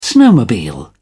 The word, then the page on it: snowmobile: English; British English